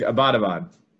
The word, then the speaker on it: Abbottabad: I have a huge Abbottabad.